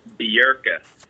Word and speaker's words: Bjerke: It is Sean BJERKE.